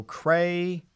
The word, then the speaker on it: crayon: How do you pronounce the thing you color with? – A crayon. – So crayon. – Crayon. – Crayon with two syllables.